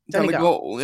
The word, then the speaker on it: Donegal: Donegal, yeah.